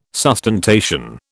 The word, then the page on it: sustentation: British English